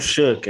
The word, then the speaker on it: Shough: We're talking football, and it's the other position we're talking a lot about, Clayton Toon, but Tyler Shook, I watched an interview where he pronounced his name and it looks like Shaw Shaw. It looks kind of funny. It's Tyler Shook, and he transferred from Oregon, and Coach Holgorsen talked about him.